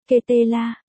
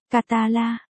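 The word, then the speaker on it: Català: Barca là viết tắt của câu lạc bộ Barcelona, tiếng Catalonia, Catala, phát âm, Ketela, hay, Catala, là một ngôn ngữ Roman,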